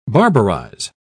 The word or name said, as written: barbarize